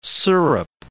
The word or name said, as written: syrup